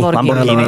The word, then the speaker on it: Lamborghini: Lamborghini.